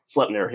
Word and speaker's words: Sletnir: Sletnir, yep. – Mm-hmm. Like slept or slut? – Slep. S-L-E-P.